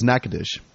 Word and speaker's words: Natchitoches: But okay, this is how you pronounce it. It's Natchitoches. That's how you pronounce it, right? – Mm-hmm. – Natchitoches Parish? – Yeah.